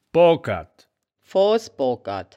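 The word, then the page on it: boogkerd: Wittlicher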